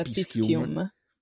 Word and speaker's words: Piscium: Puoi provare a usare la pronuncia antica. – Quindi? – Con la C dura. – Quindi Piscium.